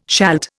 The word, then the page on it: shalt: Indian